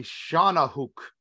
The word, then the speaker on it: Shawnahooc: I got to pronounce this properly, uh, otherwise I will get in serious trouble. So it is the Shawnahooc. Uh, so if those who want to know how to spell that, it's S H A W N A H O O C. Shawnahooc is how it was pronounced to me, which is translates as river demon.